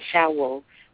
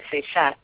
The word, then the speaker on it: Seshat: She's, you on paper it looks like Seshat because we tend to pronounce the T's. Um, but the T's are silent um, when you're pronouncing it.